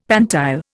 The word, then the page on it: pantile: Indian